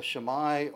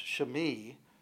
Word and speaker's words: Shimei: Why would God allow a Shammai, or actually probably better pronounced Shimei,